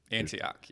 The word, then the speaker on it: Antioch: Antioch, yes.